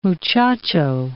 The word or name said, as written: muchacho